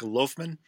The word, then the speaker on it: Loughman: Do you pronounce it like Loughman? – Uh, Loughman, kind of like a loaf of bread, but it's L O U G H. – Uh, M-A-N.